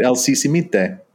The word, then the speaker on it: Sisimite: But as I traveled to the Mayan villages, I began to hear a lot about El Sisimite. How do you say that, Cliff? – Pronounce it? Yeah, that's it? Right. El Sisimite.